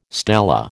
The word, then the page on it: stela: American English